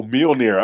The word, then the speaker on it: Mjolnir: What is, how do you say that word? Oh, Mjolnir.